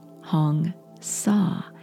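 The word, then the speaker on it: Hong Sau: Our theme this whole week is about relaxation, and it's focused on a very special ancient technique called Hong Sa, H-O-N, just like Hong Kong, and Sa, S-A-U, is pronounced like the English word saw, as if you're sawing through a board.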